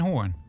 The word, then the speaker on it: Hoorn: Maar dus wel in Hoorn. – In Hoorn. Ja, is het... Ik weet dat nooit. Is het Hoorn? of horen, hoe spreek je dat uit? In Hoorn zeggen ze hoorn.